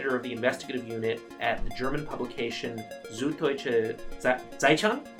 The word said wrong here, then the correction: Süddeutsche Zeitung: Did I get that almost right? – Yeah, almost. – Almost. How, what's the correct pronunciation of your organization? – It's Süddeutsche Zeitung. It's an easy word, right?